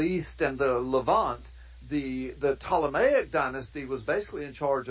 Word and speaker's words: Ptolemaic: And the Seleucid dynasty and the Ptolemaic dynasty, and Ptolemaic is spelled with a P-T-O-L-E-M-C, so it's pronounced Ptolemaic dynasty,